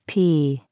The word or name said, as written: pea